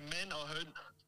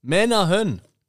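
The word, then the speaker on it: Mænd & Høns: Die Aussprache wäre Men a Hön. Men a Hön.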